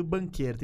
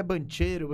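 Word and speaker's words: Banchero: Polêmica da pronúncia do nome do banqueiro. Tem gente falando que é bancheiro. Né? Banqueiro. É banqueiro porque ele é descendente de italiano.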